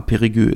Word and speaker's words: Périgueux: Und ja, das spricht man tatsächlich so aus. Ich war auch überrascht. War in den letzten Folgen nicht so ganz sicher, aber ja, Périgueux ist richtig.